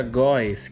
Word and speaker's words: goes: Então o verbo go vira goes, que a pronúncia é gus. Go vira gus.